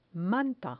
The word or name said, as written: manta